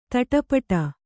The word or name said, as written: ತಾಟಿಪೋಟಿ